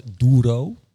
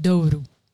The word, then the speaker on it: Doru: Dus we zeggen altijd Doro. – Zo zeggen we het omdat we denken dat het zo moet. – Doru. – Maar het is Doru.